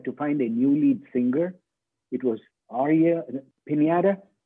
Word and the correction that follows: Pineda: Hey, it's pronounced Pineda.